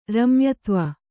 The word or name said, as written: ರಮ್ಯತ್ವ